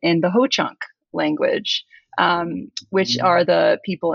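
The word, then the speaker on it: Hoocąk: So, and this is, so yeah, so in Ho-Chunk, it's in Ho-Chunk, so it is spelled H-O-O-C-A with a little tilde at the on the bottom of it, a little tail, K, but it's pronounced Ho-Chunk. So in Ho-Chunk language,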